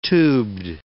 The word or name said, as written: tubed